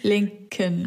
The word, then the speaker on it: Lincoln: Ich kann den nicht aussprechen. Kannst du mir helfen? Lincoln. Abraham Lincoln.